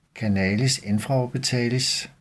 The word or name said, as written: canalis infraorbitalis